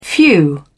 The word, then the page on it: few: British English